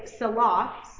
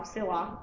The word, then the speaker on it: Selah: At the end of 40 years, the rock, the word is Selah, I'm saying that wrong, Selah.